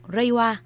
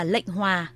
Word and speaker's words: Reiwa: Như vậy, bắt đầu từ 0 giờ ngày 1 tháng 5 tới, triều đại mới ở Nhật Bản có niên hiệu Reiwa, theo phiên âm Hán Việt có thể đọc là lệnh hòa hoặc linh hòa.